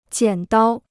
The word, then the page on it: 剪刀: jiǎn dāo